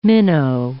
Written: minnow

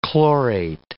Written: chlorate

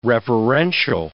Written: reverential

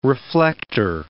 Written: reflector

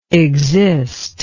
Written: exister

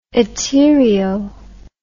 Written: etaerio

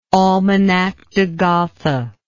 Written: Almanach de Gotha

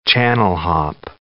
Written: channel-hop

